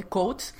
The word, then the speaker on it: Coates: es las Dora Milaje en la etapa de Cuates quieren, bueno, de Coates,